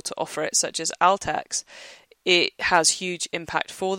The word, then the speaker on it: Altex: and Altext, who are, or even Altex, I should pronounce it properly, who do electronics, um, a big electronics retailer with both physical stores and an online opportunity.